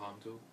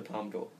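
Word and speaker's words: Palme d'Or: It was putting in competition for the, as I don't want to say it wrong, is the Palm? – It's the Palm d'Or. – It's the Palm d'Or.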